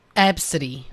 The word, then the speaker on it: Abcde: But it's pronounced ABCity. – Absidy. – Absidy. – Yeah. – Okay. – So it's quite, it's a bit of a trend in the US now. It's Absidy, spelt ABCDE.